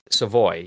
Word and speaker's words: Savoy: Savoy? – Savoy? Savoy, yeah.